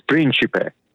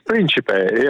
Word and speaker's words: Principe: My name, if you're in Italy, it's Principe, right? – Yeah. So say what? – Principe exactly. What? It's Principe.